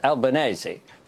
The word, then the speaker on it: Albanese: Christopher Pine actually got it right in terms of the Italian pronunciation. It's Albanese. But you don't say spaghetti bolognese, do you? So, you know. – So it is Albanese, except it's got to be pronounced that way. It's not AZ under Albanese.